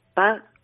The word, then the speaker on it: pas: Paz es una, se escribe PAS, es una partícula negativa, pero también significa paso.